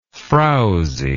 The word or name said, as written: frousy